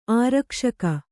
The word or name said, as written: ಆರಕ್ಷಕ